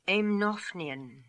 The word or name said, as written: Aimnophnian